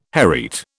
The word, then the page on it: hereat: British English